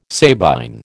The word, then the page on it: Sabine: American English